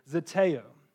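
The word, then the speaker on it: zeteo (ζητέω): I can pronounce this Greek word, zeteo.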